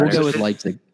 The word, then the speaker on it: Leipzig: Am I saying it right? Hopefully. – Leipzig, there you go.